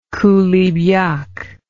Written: coulibiac

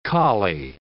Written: collie

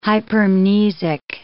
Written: hypermnesic

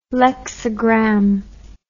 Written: lexigram

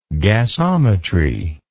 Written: gasometrically

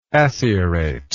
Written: acierate

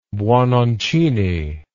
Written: buononcini

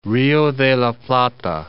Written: Plata, Rio de la

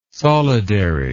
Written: solidary

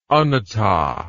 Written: anatta

